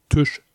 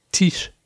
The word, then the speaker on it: Tisch: Also, für die es nicht mitgekriegt haben, das I spricht man wie ein I, nicht wie ein Ü. Deswegen heißt es nicht Tisch, sondern Tisch.